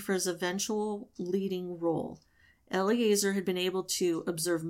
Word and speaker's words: Eleazar: And it involved, and I had to look up how to pronounce his name, Eleazar, the priest, and the specific rules of performing this purification ceremony.